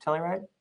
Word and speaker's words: Telluride: Telluride.